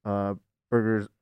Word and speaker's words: Burger: It really depends with Burgers. Is it Bert? How do you pronounce it? – Burger. Yeah. Like a hamburger.